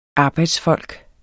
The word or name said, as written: arbejdsfolk